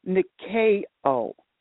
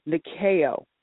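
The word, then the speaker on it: nikeo (νικάω): And it's pronounced nikeo, nikeo.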